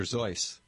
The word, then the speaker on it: Seuss: Right, or pronounced correctly, Dr. Zeus. – Dr. Zeus.